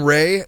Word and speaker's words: Rea: Colin Ray, is that how you pronounce his name? – Yeah. – R-E-A.